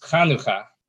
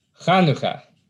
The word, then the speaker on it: Chanukah: which is pronounced Chanukah. So my challenge for you is to try to pronounce Chanukah. – Chanukah. – Yeah, it's not too bad. – I tried. – Not too bad. A little bit more emphasis on the ka at the end.